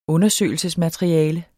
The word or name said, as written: undersøgelsesmateriale